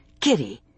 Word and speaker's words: Cire: His name's pronounced Kiri, but it's spelled C-I-R-E.